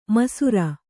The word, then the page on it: ಮೈಸಿರಿ: mai siri